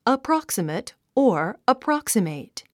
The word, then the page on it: approximate: əprάksəmət; アプラクサメト